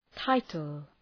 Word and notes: title: ‘taıtəl

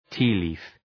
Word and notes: tea leaf: ‘ti:li:f

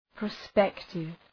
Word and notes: prospective: prə’spektıv

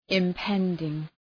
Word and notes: impending: ım’pendıŋ

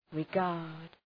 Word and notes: regard: rı'gɑ:rd

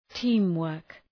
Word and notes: teamwork: ‘ti:mwɜ:rk

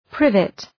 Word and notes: privet: ‘prıvıt

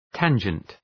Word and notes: tangent: ‘tændʒənt

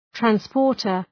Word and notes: transporter: træns’pɔ:rtər

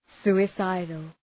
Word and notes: suicidal: ,su:ı’saıdəl

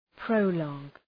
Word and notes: prologue: ‘prəʋlɔ:g

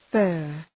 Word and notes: fir: fɜ:r

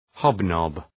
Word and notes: hobnob: ‘hɒb,nɒb

